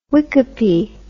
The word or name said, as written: wickape